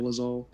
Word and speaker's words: Dolezal: Dolezal, let me know if I pronounced it wrong. – Dolezal.